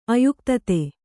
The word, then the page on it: ಅಯುಕ್ತತೆ: kannada